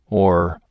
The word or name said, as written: oar